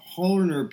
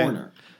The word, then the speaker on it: Hohner: And that electric piano is do you say Hofner or Hohner? Because I've always heard Hofner bass, but like a Hohner harmonica or something. – I don't know. I always pronounce it. It's a Hofner bass and a Hohner piano, I think.